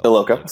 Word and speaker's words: Iloka: George Iakoa? Did I say that right? The Bengals safety just got. – I think it's Iloka. I think it's just regular. It's pronounced like it's spelled Iloka.